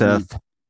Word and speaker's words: Hegseth: Hegseth.